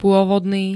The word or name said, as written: pôvodný